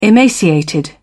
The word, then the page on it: emaciated: British English